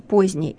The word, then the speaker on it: поздний: Или правило о непроизносимых согласных, как в случае со словом «поздний». Это «д» я не слышу, «поздний», но я его пишу «поздний». Почему? Потому что в слове «опоздать» это «д» я слышу.